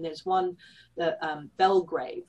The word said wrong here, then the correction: Blagrave: I think you pronounce his name, Blagrave. – Blagrave.